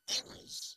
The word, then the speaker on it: errors: Bien, errors, se pronuncia tal y como lo acabo de decir yo.